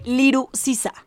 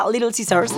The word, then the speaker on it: Little Caesars: pero registra el nombre Liru Sisa. O sea, seguramente mucha gente que no lo pronuncia correctamente y no pronuncia Little Caesars, pronuncia Liru Sisa.